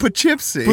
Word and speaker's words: Poughkeepsie: Poughkeepsie.